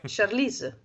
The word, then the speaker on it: Charlize: Eh, Charlize, giusto è eh, la pronuncia? – Bravissimo, assolutamente sì.